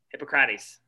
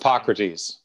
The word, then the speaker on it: Hippocrates: Hippocrates. Why am I saying his name wrong? – It's just Hippocrates.